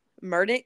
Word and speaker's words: Murdaugh: And, and y'all looked at me funny how I had heard it called uh, Murdaugh. Yeah. They literally pronounce it that way themselves.